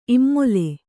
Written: ಇಮ್ಮೊಲೆ

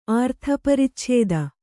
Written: ಆರ್ಥಪರಿಚ್ಛೇದ

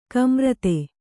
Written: ಕುಮಾರಿತಿ

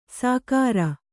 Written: ಸಾಕಾರ

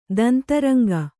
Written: ದಂತರಂಗ

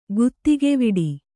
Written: ಗತಕವೆಟ್ಟು

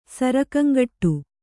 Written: ಸರಕಂಗಟ್ಟು

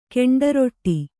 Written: ಗಂಡುರೊಟ್ಟಿ

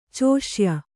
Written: ಚೋಷ್ಯ

